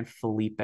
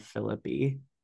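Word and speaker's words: Phillippe: I also really like Shoshana saying Ryan Felipe instead of Philippi.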